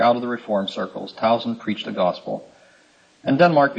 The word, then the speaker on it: Tausen: Tausen, just for the sake of record, is spelled T-A-U-S-E-N, one S, I think. Tausen.